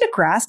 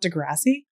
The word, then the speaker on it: deGrasse: Is it DeGrasse, not Degrassi? – Yeah, it's DeGrasse. – Damn. There was a TV show in Canada called Degrassi High, so I've been saying his name wrong my whole life.